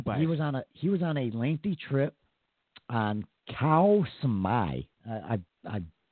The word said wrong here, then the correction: Koh Samui: Oh, Koh Samui.